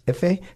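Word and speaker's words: Ife: Efe? Yes. Did I say that right? – Yes, yes.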